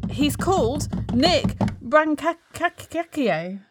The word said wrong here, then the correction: Brancaccio: I don't know how you pronounce his name. I'm so sorry, Nick. – Brancaccio. – Oh, that's what I was trying to say.